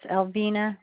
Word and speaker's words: Alvina: It's Alvina Ritzman. – Can you spell that, please? – A-L-V-I-N-A.